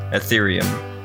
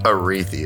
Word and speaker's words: Ethereum: Ethereum. – Aretheum.